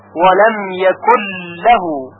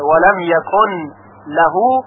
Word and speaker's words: ولم یکن له: یعنی نون ساکن یکن در لام لهو ادغام میشه، بنابراین فقط یک لام مشدد شنیده میشه، یکن لهو یکل له، یعنی نون دیگه تلفظ نمیشه. ولم یکل له، البته اگر کسی ادغام نکنه و بخونه ولم یکن له، نماز او اشکالی نداره.